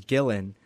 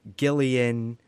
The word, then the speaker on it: Gillen: But my full name is Gillen. When people read that, I get Gillen, Gillian, and some people just Guillaume if they think I'm French.